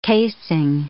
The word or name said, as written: casing